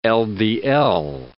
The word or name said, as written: LDL